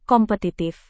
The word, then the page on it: kompetitif: indonesian